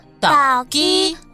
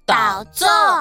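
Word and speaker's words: 豆棗: 豆枣，台语念作倒鸡或倒座，下次就可以直接说我要吃豆枣喽。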